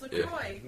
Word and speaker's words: LaCroix: It's LaCroix.